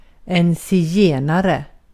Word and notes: gypsy: American English; [ˈdʒɪp.si]; /ˈd͡ʒɪp.si/

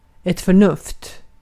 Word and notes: reason: /ˈɹiː.zən/; [ˈɹiː.zən]

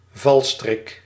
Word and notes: piège: France; [pjɛʒ]

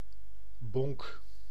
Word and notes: morceau: [mɔʁ.so]

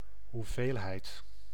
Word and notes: amount: American English; /əˈmaʊnt/

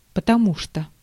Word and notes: потому что: [pətɐˈmu ʂtə]; potomu tšto

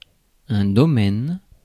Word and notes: domain: /doʊˈmeɪn/; [doʊˈmeɪn]; /dəʊˈmeɪn/; /dəmejn/

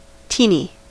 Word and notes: teeny: American English; [ˈtiː.ni]

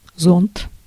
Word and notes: umbrella: American English; [ʌm.ˈbɹɛ.lə]

